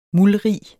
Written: muldrig

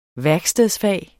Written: værkstedsfag

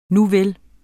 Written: nuvel

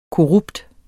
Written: korrupt

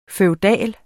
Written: FEUDALE

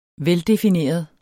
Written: veldefineret

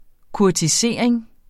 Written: kurtisering

